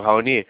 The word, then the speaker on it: Raoni: Eu estou falando com o senhor Rawone. – Isso. – Ok, daí eu pronunciei certo? Rawone, Rawone, né? – Isso, Raoni. – Ok, seu Raoni, boa tarde, eu me chamo Felipe Melo, tá?